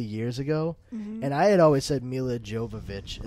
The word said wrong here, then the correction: Jovovich: at that point, and she straight up said to him, like, that's not how my last name is pronounced. It's Jovovich. And he was like, oh, really? And she said, yeah, the best way to remember it is just say, yo, you bitch.